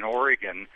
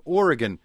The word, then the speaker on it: Oregon: And you also, thank you for pronouncing Oregon correctly. That's one I had to work on after getting many nasty emails.